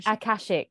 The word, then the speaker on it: Akashic: So that's why when we spoke about the I never say it right. Akashic, there we go.